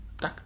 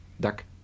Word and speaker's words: Doug: Wie spricht man diesen Vornamen aus? – Was? – Dieser Lombardi. – Duck. – D-O-U-G heißt der mit Vornamen. Duck. – So wie Donald Duck.